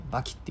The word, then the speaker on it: 刃牙: このバキっていうのは、刃とあの牙って書いて、バキって読むね。えー、 17 歳ぐらいだったかなの格闘家が主人公のね、話なんですけど、えー、最初はグラップラーバキって始まって、